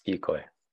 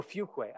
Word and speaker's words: Fuquay: Yes, Fuquay. – Oh, Fuquay.